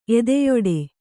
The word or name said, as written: ಎದೆಯೊಡೆ